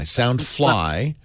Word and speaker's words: Soundfly: I'm sorry, I said that wrong. It looked like Soundify. Soundfly, and Amazon sells it for $45.